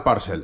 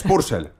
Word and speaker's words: Purcell: Por cierto, Nicholas Jackson, yo estaba harto de Parcel, dice usted mal Parcel, es Purcell, Purcell, dice usted mal...